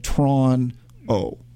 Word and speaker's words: Toronto: I was like, being from Toronto, they say Toronto, not Toronto. You know, like we only use two syllables. – Toronto. – Instead of Toronto. It's like you run it to. – It's two syllables. – Yeah. – They shorten it up. It's Toronto. – Yeah. – It's like Tron-O.